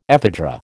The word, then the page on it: ephedra: American English